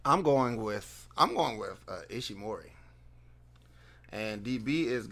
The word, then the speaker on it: Ishimori: Ishimori? – Yeah. – Yeah, you get 5 points for being able to pronounce that.